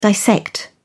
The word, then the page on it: dissect: British English